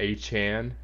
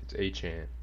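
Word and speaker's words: Achane: I guess that's how you say his name now. Yeah, I guess he corrected it because, you know, he had an interview, I'm sure, and he wanted to get it straight. So, yeah, it's A-chan.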